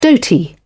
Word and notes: dhoti: British English

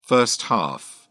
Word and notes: first half: British English

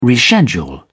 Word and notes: reschedule: British English

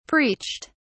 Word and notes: preached: British English

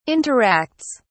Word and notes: interacts: British English

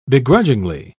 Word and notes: begrudgingly: British English

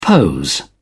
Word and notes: pose: British English

